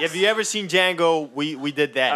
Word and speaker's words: Django: It's Django. The D is silent.